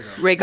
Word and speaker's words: Rhaegal: Away from the wretched city that led to the death of his mother and brother Rhaegal? Is that how you pronounce it? – Rhaegal.